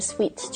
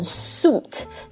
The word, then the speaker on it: suite: 然后第二个那个 suite 就是一个酒店里面的大套房。 – Right. – 就这个我觉得这个 suite 套房很容易读成 suite，就是它其实是 suite.